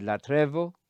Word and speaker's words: latrevo: Religious service, as described by the Greek word latrevo, I'm using the modern Greek pronunciation, as we customarily do.